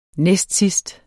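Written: næstsidst

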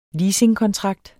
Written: leasingkontrakt

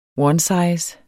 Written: onesize

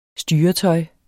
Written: styretøj

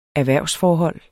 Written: erhvervsforhold

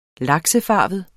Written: laksefarvet

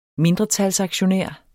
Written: mindretalsaktionær

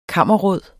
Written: kammerråd

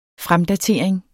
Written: fremdatering

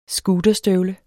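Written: SCOOTERSTØVLE

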